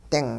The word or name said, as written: -ding